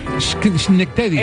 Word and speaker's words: Schenectady: Schenectady.